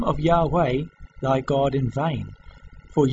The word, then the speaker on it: Yahweh: while inclining to view the pronunciation Yahweh as the more correct way, we have retained the form Jehovah because of people's familiarity with it since the 14th century. Even Mr. Stephen T. Byington, from the Bible in Living English published by the Jehovah's Witness Watchtower and Bible Tract Society, in the preface, states that the pronunciation Jehovah is a blunder.